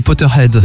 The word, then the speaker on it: Potterheads: Les Potterheads, c'est Potter, ça? – Exactement. – Je le dis bien. Oui.